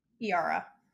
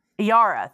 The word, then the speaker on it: Yara: And Mistress I keep saying it wrong. – Yara. – Yara, thank you.